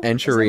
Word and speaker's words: Enchirito: But say it again, enchirito? – Enchirito.